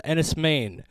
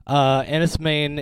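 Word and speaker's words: Enys Men: This is a movie called Enies Mein, that's spelled E-N-Y-S space M-E-N. Enies Mein is the film.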